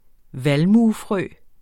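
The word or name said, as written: VALMUEFRØ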